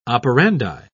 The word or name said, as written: operandi